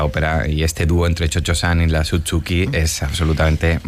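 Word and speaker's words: Cio-Cio-san: Se escribe Cio. Pero como claro, la pronunciación italiana como Chocho-san.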